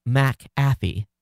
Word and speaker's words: McAfee: He says, McAfee is a Scottish name. Thus, MC is a prefix. It's pronounced separately. So the C is not added to the second syllable. That means McAfee is pronounced McAfee. A few moments later, you correctly pronounce McHenry. The MC in McAfee is pronounced the same way, McAfee.